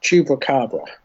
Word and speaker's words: Chupacabra: Chupacabra.